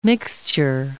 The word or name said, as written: mixture